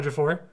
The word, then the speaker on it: Ejiofor: I know his last name is Ejiofor. – Chiwetel, thank you. Chiwetel Ejiofor.